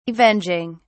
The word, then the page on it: avenging: British English